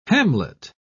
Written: hamlet

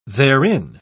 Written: therein

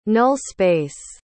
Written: null-space

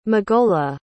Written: magola

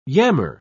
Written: yammer

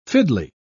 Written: fiddly

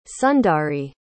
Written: sundari

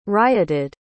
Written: rioted

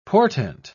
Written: portent

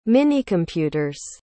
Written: minicomputer's

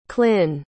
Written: Klynn

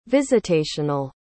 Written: visitational

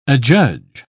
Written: adjudge

